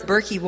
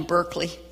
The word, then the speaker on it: Berkey: But just keep it in mind that's you've heard of Berkey water filters. Am I saying that right? Finally. I've been calling them Berkeley.